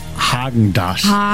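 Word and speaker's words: Häagen-Dazs: Ich sage Hagen das. – Oh, okay. Spricht man das mit R oder mit A aus? – Man spricht es mit A aus. Ich sage auch, es heißt Hagen das.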